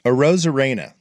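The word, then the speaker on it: Arozarena: A Rosarena. That's how I say it. – I think that's the correct way to say it, is a Rosarena.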